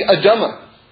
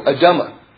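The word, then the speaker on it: adama (אדמה): I once said one time that the schach It's got to be adama. That's vegetation. That's how you say it in Hebrew, adama.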